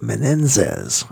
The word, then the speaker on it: Menezes: And then we had Artur Menendez. Or actually, it's pronounced Menendez, I believe. M-E-N-E-Z-E-S from Brazil, blues guitar player, and will be up at Biscayne Blues in San Francisco.